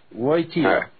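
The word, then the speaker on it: Wojtyła: Wojtyla.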